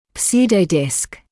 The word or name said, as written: pseudodisk